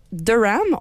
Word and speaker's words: Durham: Durham, on le prononce encore en anglais, alors que Dufresne, on le dit en français.